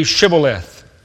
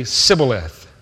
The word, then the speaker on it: Shibboleth: They would say, pronounce the word for me, Shibboleth. And they would say, Sibboleth.